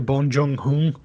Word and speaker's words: Bong Joon-ho: You know, I think the director, Bong Joon-ho, right? I think I said that right. – Yep.